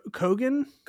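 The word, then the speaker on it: Keoghan: Keon Keegan. – Hold on, Barry Cogan. – Cogan, maybe. Yeah.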